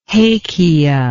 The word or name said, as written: hakea